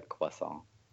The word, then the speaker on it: croissant: Usually not with a hard R, with the like the croissant.